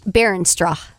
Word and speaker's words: Beerenstrauch: I do believe Bierenstrach. – Spell it. – B-E-E-R-E-N-S-T-R-A-U-C-H, Berenstrauch.